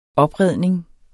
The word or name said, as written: opredning